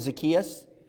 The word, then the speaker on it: Zacchaeus: Give us the correct non-American pronunciation of Zacchaeus. Okay, all right. I'm going with Zacchaeus. You're right, you're good.